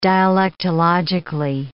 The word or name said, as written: dialectologically